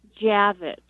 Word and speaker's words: Javitch: Yes, it's Karen Javitch.